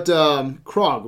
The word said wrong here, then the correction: Korg: Korg. – Korg.